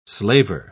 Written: slaver

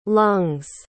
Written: lungs

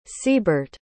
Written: seabert

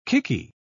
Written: kicky

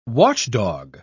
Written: watchdog

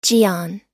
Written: jeon

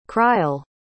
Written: crile

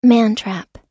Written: mantrap

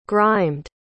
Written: grimed

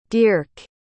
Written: dierk

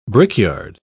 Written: brickyard

